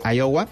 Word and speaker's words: Iowa: ¿Así se pronuncia? ¿Iowa? – Iowa. Ajá.